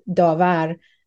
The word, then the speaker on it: Davar: In Farsi, it's pronounced Davar,